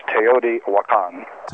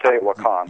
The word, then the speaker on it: Teotihuacan: It's kind of like peyote, but it's Teotihuacan. – Okay, you got Teotihuacan. It. Teotihuacan.